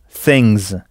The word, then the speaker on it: things: Et une chose, a thing, des choses, things. Dans ce cas, pour des raisons de sonorité, le S final se prononce z. Things.